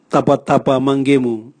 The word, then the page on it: tabwatabwa mangemu: Raga